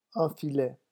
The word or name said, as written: un filet